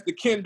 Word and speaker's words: Qin: All right, and I'm glad you did that because, all right, the name King of Ken being spelled Q-I-N, that's the Ken Dynasty of China.